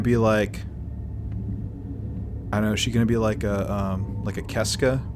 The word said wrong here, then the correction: Seska: Did I say that right? – Seska. – Sorry, God. Seska.